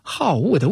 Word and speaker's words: 好恶: 而三字经里面所提到的这个爱啊，我们注意啊，它后面紧跟着是物啊，就是善恶的恶，在这儿是多音字，它是好恶的恶。